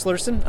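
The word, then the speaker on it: Lursen: Yeah, my name is Chris Lurson.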